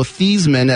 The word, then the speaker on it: Theismann: who was Joe Theismann at Notre Dame, but changed the pronunciation, true story, to get a better chance of winning the Heisman. Like some guy's going to vote, Heisman, Theismann, I like that.